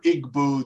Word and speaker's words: Igbo: Um, for example, there is a tribe along the Niger River of a group of Africans called the Ibo or Igbo, depending on how you pronounce it.